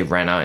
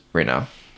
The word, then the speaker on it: Rinna: Yeah, you say Jen Shaw and you also say Renner instead of Rinna.